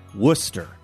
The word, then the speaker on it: Worcester: By the way, story sent to us by, I want to say, listener Chris in, wait for it, that's right, Worcester. Pronounced Worcester, Massachusetts.